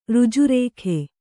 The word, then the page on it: ಋಜುರೇಖೆ: Kannada